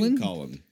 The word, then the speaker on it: Lincoln: How do we pronounce it Lincoln when that's not how it's spelled? – Are we going to get into this now? – Damn you, English. But yeah, Lincoln. And the reason why is there really aren't other words that work that way. You're right, though. I'm trying to think of other words that use O-L-N with a silent L.